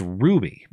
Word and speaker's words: RWBY: Chaos Lear Len says, there's a show called RWBY, which is pronounced Ruby.